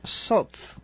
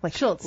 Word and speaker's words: Schultz: We just got a live update on pronunciation for Brad. It's Schultz. – Schultz. – Like the Peanuts cartoonist.